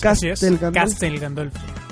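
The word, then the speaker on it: Castel Gandolfo: así es, Castelgandolfo. Ah, lo dije bien.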